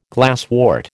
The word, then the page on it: glasswort: American English